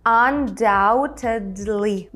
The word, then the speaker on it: undoubtedly: Ну і ще додамо undoubtedly безсумнівно. І тут важливо пам'ятати, що ми там літеру B не вимовляємо undoubtedly, безсумнівно.